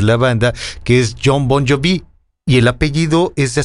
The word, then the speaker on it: Bon Jovi: Entonces esta persona me decía, no es Bon Jovi, es Bon Jovi.